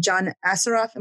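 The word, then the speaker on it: John Assaraf: I actually wish that something you had said in one of your interviews, in one of your podcasts with that gentleman, John Assaroff, am I pronouncing it right? – Yeah.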